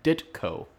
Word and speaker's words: Ditko: I hope I say it right, but Steve Ditko. – Yep. – You pronounce the T.